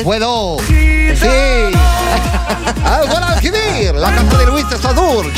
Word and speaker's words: Guadalquivir: Le Guadalquivir. – Le Guadalquivir.